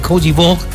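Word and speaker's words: Côte d'Ivoire: Ya hay alineaciones por parte de Alemania ante Cogi Bog. Así se pronuncia en François.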